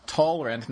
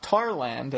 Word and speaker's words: Talrand: He, exactly, the sky summoner himself, uh, Talrand, not Tarland,